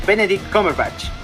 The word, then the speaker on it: Cumberbatch: Benedict Cumberbatch.